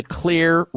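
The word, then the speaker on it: Xlear: Clear is spelled with an X, X-L-E-A-R, rescue nasal spray.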